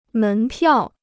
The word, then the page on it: 门票: mén piào